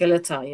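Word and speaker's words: Skeletá: Yeah, Skeleta, I think is how you pronounce it. – Skeleta, yes.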